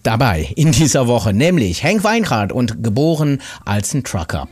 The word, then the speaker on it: Henk Wijngaard: Da kommt er her aus Staatskanal und hier ist Henk Wijngaard. So wird er nämlich richtig ausgesprochen.